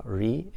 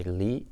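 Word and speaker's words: ri (理): Or actually that the poem refers to, the Japanese pronounce ri and ji, or the Chinese say li and shi.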